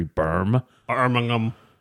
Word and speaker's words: Birmingham: particularly in a town called Birmingham. I think I'm saying that right, or maybe Birmingham. – Birmingham.